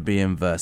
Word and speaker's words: BMW: Yeah, BMW. Because we've been. They pronounce their W, V. In the German language.